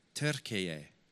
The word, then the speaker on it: Türkiye: Or should I try and pronounce how you meant to pronounce Turkey now? Türkiye. Is it close? Yeah, it's close.